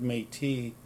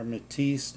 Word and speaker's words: Métis: For those that don't know, the word Métis is or Métis, they pronounce it at times.